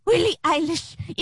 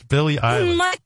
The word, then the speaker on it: Billie Eilish: Yes, and I need to talk to Willie Eilish immediately. – Willie Eilish? – Willie Eilish? No, Willie Eilish. – No, Bill. Her name isn't William, it's Billy. It's Billie Eilish.